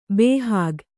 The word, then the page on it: ಬಹುªÀುುಖಿ: baumukhi